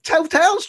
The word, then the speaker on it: Total: But when you sit here and take a look at the majors, BP, Total, did I say that right? Total? Yeah. Okay. Total's changing their name.